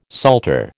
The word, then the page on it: salter: American English